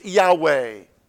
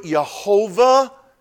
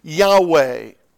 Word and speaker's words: Yahweh: Now, no one's exactly sure how it's pronounced because the Old Testament was written without vowels. But I think scholars are pretty much set that the best pronunciation is Yahweh. And I think some of your Bible translations may do it Jehovah. And that's fine, but I think it's best that it is Yahweh.